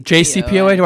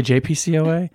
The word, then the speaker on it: JCPOA: JCPOA? – Why did I say JPCOA? Yeah, I haven't had scotch yet this morning.